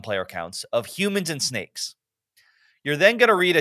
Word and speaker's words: Snakesss: First one is Snakes. This is a weird trivia game. – Hold on. I don't think you said it right. – Oh, sorry. Snakes. – There we go.